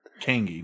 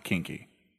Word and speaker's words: Kangi: Kangi, not Kinky. Kangi? Like King? Like yeah.